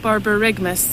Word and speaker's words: borborygmus: This, borborygmus. – That's such a lovely voice. – It's really a nice voice. – One more time. – Oh, one more time. – Borborygmus.